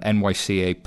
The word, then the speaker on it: NYCA: So they're an enterprise blockchain startup, and uh, the funding's led by Goldman and NYCA Partners. Um, is that pronounced Nyc? yeah. yeah.